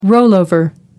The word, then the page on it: rollover: American English